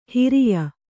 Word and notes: ಹರೆಯ: hareya

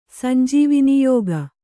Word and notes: ಸಂಜೀವನಯೋಗ: sanjīvana yōga

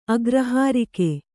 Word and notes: ಅಗ್ರಹಾರಿಕೆ: agrahārike

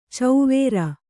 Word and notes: ಚೌವೇರ: cauvēra

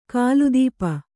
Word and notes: ಕಾಲುದೀಪ: kālu dīpa

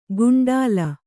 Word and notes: ಗುಂಡಲ: kannada

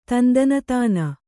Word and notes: ತಂದಾನತಾನ: tandānatāna

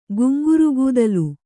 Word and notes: ಗುಂಗುರುಕೂದಲು: Kannada

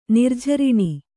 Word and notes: ನಿರ್ಜೀರ್ಣ: nirjīrṇa

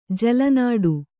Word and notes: ಜಲನಾಡು: jala nāḍu

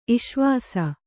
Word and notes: ಇಷ್ವಾಸ: iṣvāsa

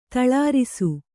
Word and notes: ತಳರಿಸು: taḷarisu

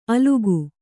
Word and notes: ಅಲುಕು: Kannada

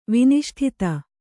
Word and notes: ವಿನಿಷ್ಠಿತ: viniṣṭhita

